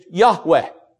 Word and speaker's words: Yahweh: The name, they think the probably correct pronunciation is Yahweh.